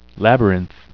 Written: labyrinth

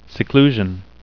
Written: seclusion

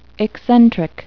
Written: eccentric

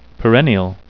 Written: perennial

